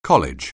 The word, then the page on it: college: anglais